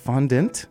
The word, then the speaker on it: fondant: Fondant. – Fondant. – Your mouth is being lazy. It's fondant.